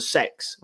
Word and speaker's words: CEX: It's basically across the road from sort of like the Boot and McDonald's in that sort of square, across the road from CEX, which apparently is pronounced sex, but I'm not going to say that.